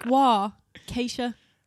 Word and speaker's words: Waukesha: I actually looked it up. It's like Waukesha.